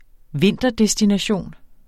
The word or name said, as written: vinterdestination